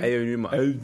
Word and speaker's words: Aonuma: Aonuma.